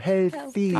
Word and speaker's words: healthy: 헬피하세요. 아. 지금은 건강해 보이시고. – 네. – TH 발음 그렇게 다시 한번 healthy. healthy boy. – 해 한 다음에 L 발음은 하셔야죠. healthy.